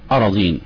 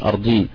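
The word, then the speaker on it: أراضين: اراضين مش ما انطقهاش ارضين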